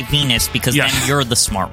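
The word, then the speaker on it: Venis: Or it rhymes with penis. – Yes, like, you get it. – But you don't say that. You only say Venus.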